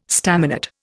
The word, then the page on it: staminate: Indian